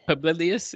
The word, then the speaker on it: Publilius: Publilius, yes.